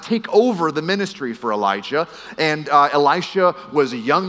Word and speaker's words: Elijah: So before Elisha, his teacher was a prophet named Elijah with a hard J.